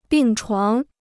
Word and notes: 病床: bìng chuáng

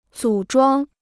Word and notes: 组装: zǔ zhuāng

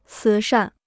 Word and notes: 慈善: cí shàn

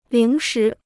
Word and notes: 零食: líng shí

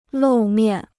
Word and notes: 露面: lòu miàn

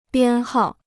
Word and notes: 编号: biān hào

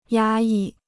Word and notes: 压抑: yā yì

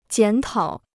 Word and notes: 检讨: jiǎn tǎo

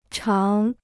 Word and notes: 常: cháng

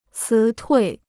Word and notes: 辞退: cí tuì

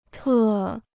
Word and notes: 特: tè

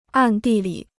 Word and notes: 暗地里: àn dì li

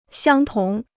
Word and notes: 相同: xiāng tóng

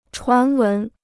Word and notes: 传闻: chuán wén

